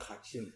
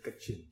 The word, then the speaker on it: Kachin: Not Kachin, Kachin.